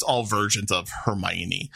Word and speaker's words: Hermione: It's Hermione.